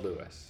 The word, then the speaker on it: Lewis: It's not Louis. It's spelled Lewis. – Yeah, it's L-E-W-I-S. – Lewis Hamilton. – Oh. – Yeah, that's hardcore Lewis. Yeah. That's just straight up Lewis.